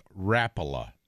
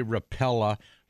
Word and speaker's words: Rapala: Like Rapala is coming, and yes, folks, it's pronounced Rapala. You can say Rapala, you can say Rapella. We don't care how you say it. We know what you're talking about, but the correct pronunciation is Rapala.